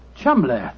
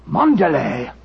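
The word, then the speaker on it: Cholmondeley: Being an American, he had no reason to know that the name Chumley is in no way pronounced the way it is spelt. – No, Joe, I never thought of that. Chumley. That name spelt C-H-O-L-C-H-O-L-M-O-N-M-O-N-D-E-D-E-R-L-E-Y. Charles Mandalay. Mandalay. – Precisely, old fellow. What you thought to be Mandalay was really Charles Mandalay, the name of the murderer.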